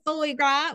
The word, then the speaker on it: foie gras: How do you say foie gras? What is it? – Say it again. Foie gras.